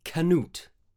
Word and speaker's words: Canute: Try pronouncing it Canute. – Okay, Canute. Oh yes, much better.